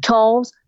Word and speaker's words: Charles: And my website, if you just Google my name, Wynn Charles, C-H-A-R-L-E-S, everything will pop up about me.